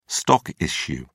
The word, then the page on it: stock issue: British English